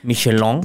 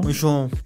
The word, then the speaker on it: Michelin: o como ellos lo pronuncian, Michelin, Michelin.